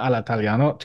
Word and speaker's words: all'italiano: Hello, everybody, and welcome to Cinema Italia, a podcast which is reveling and celebrating in everything all'italiano, cinema all'italiano, or cinema all'italiano, to give it the correct pronunciation.